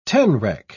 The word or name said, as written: tenrec